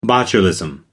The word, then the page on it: botulism: American English